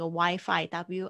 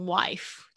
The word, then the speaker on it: Wi-Fi: 我们之前有讲到这个 Wi-Fi，W I F I，不要念成 wife，就变成老婆了。